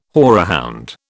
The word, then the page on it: horehound: British English